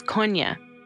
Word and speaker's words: Kony: The proper pronunciation is Konya.